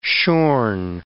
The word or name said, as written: shorn